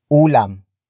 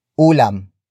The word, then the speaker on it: ulam: It is the focus marker that introduces the main topic of the sentence, and that is ulam. Ulam. Ulam. Ulam is spelled as U-L-A-M.